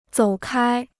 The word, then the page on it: 走开: zǒu kāi